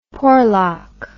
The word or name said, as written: Porlock